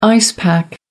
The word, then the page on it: ice pack: American English